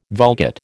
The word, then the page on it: Vulgate: American English